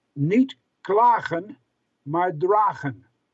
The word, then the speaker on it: niet klagen, maar dragen: Okay, the pronunciation of that scratching that he did on the wall was... niet klagen, maar dragen. Niet, N-I-E-T, stands for N-O-T. K-L-A-G-E-N stands for complain.